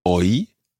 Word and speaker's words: hoy: So the first line is Navidad, Navidad, hoy es Navidad.